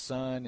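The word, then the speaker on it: sun (σύν): The Greek word with is sun, S-U-N. It's not pronounced sun. It's got kind of an E sound in it, sun.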